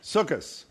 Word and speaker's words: Sukkoth: Sukkot or Sukkoth? – Sukkoth. But some people, Sukkoth is how I pronounce it when I was a little Jewish boy growing up.